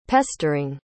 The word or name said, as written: pestering